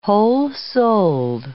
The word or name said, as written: whole-souled